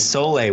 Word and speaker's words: sole: For electrolytes, if someone is eating keto, have you used soul water with your patients? – Yeah, I have actually. And the technical pronunciation is sole water.